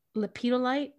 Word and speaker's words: lepidolite: It says spell ingredients, make a bouquet of wildflowers and weeds, lepidolite, is that how you pronounce it? – Yes.